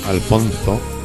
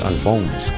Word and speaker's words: Alfonso: La música del saxofonista norteamericano Alfonso, se escribe Alfonso, Alfonso Blackwell. No es Alphonse, como sería en inglés.